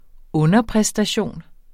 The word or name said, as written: underpræstation